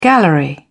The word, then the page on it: gallery: British English